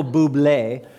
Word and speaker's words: Bublé: I liked it in the 90s when this new guy from Sonoma County, Michael Bubbly, oh, I'm sorry, I didn't pronounce it right. That would be a Sonoma County pronunciation. Michael Bublé, when he sang, "It's Beginning to Look a Lot Like Christmas."